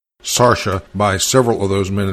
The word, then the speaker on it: Saoirse: and which I pronounce Sarsha Undaban. Now, he tells me that my pronunciation of the word S-A-O-I-R-S-E is incorrect, and it should be Saoirse or Saoirse or Saoirse, but never Sarsha. Well,